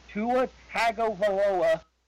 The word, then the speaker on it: Tagovailoa: With a strong performance against LSU this Saturday, Tua Tagovailoa wraps up the Heisman Trophy.